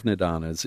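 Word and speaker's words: Nidanas: But and tell us about and I don't know if I'm saying this correctly, the 12 Nindanas. – Yeah, the 12 Nindanas.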